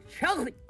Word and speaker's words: chocolate: 살짝 넣고 chocolate. – 그건 아예 없어졌고. – 아, 살짝 넣었잖아. – Chocolate. – 초콜릿. – 네, 이 차, 그렇죠. CHO 차로 발음해야 됩니다.